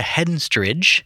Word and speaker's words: Henstridge: Featured Natasha Henstridge.